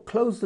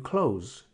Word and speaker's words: clothes: If you say it like you shut a door, close the door. I'm going to buy new clothes.